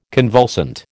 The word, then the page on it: convulsant: American English